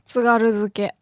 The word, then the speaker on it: tsugaruzuke: Tsugaruzuke. – Can you break it down? – Tsugaruzuke. – What is tsugarezuke? – No, tsugaruzuke. – Tsugaruzuke.